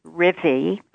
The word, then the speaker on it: Rivvy: Well, my name is Rivvy, which is spelled R-I-V as in Virginia, V as in Virginia, Y.